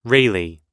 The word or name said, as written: Rayleigh